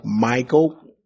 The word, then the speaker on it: Michal: But because today saying his wife, Michal, has different kind of meanings and connotations today, now we got to pronounce her name properly, Michal. Because if we say Michal, oh, we think it's something different today. David's wife, Michal.